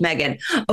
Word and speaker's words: Megan: Like, and I don't know if that's some sort of like regional pronunciation, but I'm like, I'm just a regular old Megan.